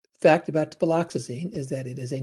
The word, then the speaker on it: viloxazine: What can you tell us about Viloxazine. Is that how it's pronounced? – Viloxazine? Yes, it's it's it's uh, viloxazine or extended release viloxazine because it's uh,